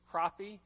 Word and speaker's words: crappie: because we were fishing for crappie. I said it right, crappie fishing. You Georgia people don't know how to pronounce words, but anyway, it's crappie fishing.